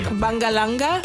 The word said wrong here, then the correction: Bangaranga: Bangaranga. – Bangaranga? – But it's pronounced like Bangaranga.